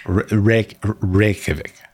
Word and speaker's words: Reykjavik: Now I'm self-conscious about Reykjavik.